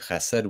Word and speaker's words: chesed: And Ajahn Kovilo, your pronunciation of chesed was literally perfect. You got the consonant.